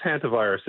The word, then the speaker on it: hantavirus: I think it's called hantavirus, but I never know how scientists pronounce things. – Yeah, that's right. It's just hantavirus.